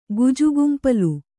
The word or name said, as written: ಗುಜುಗುಂಪಲು